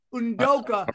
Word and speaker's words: Udoka: Udoku has to be, or what's his name? How do you say Udoka. – It? Udoka. Udoka has to be the dumbest scumbag in the history of dumb scumbags, okay? – And that's me and Sam saying that.